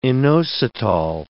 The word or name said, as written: inositol